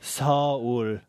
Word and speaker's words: Seoul: Did you know that Seoul is actually two syllables in Korean? – No. – It is. – What's going on? – Seoul.